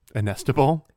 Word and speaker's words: inestimable: Inestimable? – Inestimable. – I left out at least one syllable.